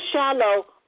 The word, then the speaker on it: Shiloh: Shiloh unto all the Israelites that came therein.